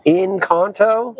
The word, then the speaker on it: Encanto: Encanto. Yeah. – Encanto. And then we are going to record the second half of the show after we watch it. So we'll give you a review of Encanto on Ice.